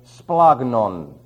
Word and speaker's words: splagnon: But the second one is splagnon. That's S-P-L-A-G-N-O-N. And the last one is nephros. That's N-E-P-H-R-O-S.